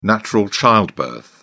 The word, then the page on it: natural childbirth: British English